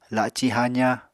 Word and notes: laci haza: Apatani

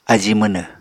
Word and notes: ají mwnw: Apatani

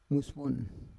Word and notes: mues von: Roon

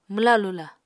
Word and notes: mwla-lula: Apatani

